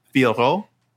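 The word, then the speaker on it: Fiorot: But it is uh Fioro. People, if you can if you can roll your R's a little bit too, would be nice.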